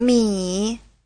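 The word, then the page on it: 米: mi3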